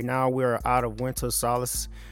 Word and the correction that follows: solstice: and I don't like solstice. That's how you say it winter solstice, and I pretty much don't like the winter time.